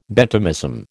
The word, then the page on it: Benthamism: American English